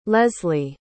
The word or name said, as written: leslie